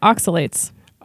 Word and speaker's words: oxalates: but oxalates. Did I say that correctly? – Yeah. Oxalates.